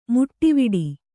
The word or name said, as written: ಮೀಟುವಡೆ